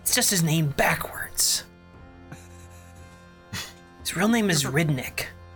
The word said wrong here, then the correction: Ryndik: Sorry, I said that wrong. Rindick. – I'm emphasis on the dick. – R-Y-N-D-I-K.